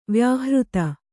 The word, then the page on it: ವ್ಯಾಹೃತಿ: Kannada